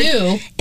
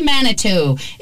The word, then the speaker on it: Manitou: G-I-T-C-H-I-E. – Okay. – And Manitou, because separate word. – Manitou. – M-A-N-I-T-O-U. – Okay, so Gitche Manitou.